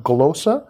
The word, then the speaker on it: glossa: And the word tongues just comes from a Greek word called that's pronounced glossa.